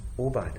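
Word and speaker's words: Aubade: I was told it's pronounced Obad.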